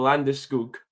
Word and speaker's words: Landeskog: And then after that was Gabriel Landeskog. – Right. – I'm saying it correctly now because obviously all their wee videos that have been going online, which is fucking hilarious.